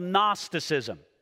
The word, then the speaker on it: Gnosticism: Gnosticism is spelled G-N-O-S. It begins that way. So we don't pronounce the G, but it's spelled Gnosticism.